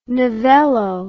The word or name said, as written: novello